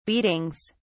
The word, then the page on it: beatings: British English